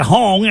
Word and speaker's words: Hong: And I said Hong, and then rhymes with ding dong.